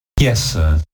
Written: yessir

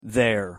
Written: their

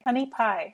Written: honey pie

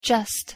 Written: just

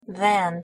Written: than

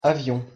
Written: avion

